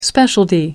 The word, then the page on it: specialty: American English